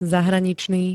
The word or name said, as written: zahraničný